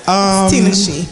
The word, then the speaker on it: Tinashe: Is that how you say it over here? Her name is her name. – It's Tinashe.